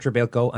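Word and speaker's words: Trebilcock: Imagine my juvenile delight when I later saw his name in print and discovered that the man whose name was innocuously pronounced Trebilco was in fact spelled Treblecock. From that day hence, he was inevitably known to me as Mike Treblecock.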